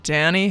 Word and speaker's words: Dhani: And they had a son named Danny Harrison. – Spelled with a D-H. – Yeah. – D-H-A-N-I. What a weird way to spell Danny.